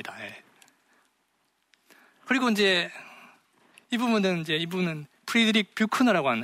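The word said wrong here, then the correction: Buechner: 하는 아 비크너입니다. 이름은 이름을 발음하기 되게 어려운 분인데 비크너라고 본인이 주장합니다.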